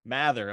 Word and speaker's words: Mather: Mather.